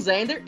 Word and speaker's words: Xander: Xander, em inglês se escreve Xander com X. E, e os times eles estão achando isso uma oportunidade maravilhosa. Só que maravilhosa estilizado com o nome da Marvel, porque o Zander é o X-Men.